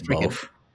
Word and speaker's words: Balfe: I always just say Balfe. – Just Balfe? Yeah, the Balfe. Yeah.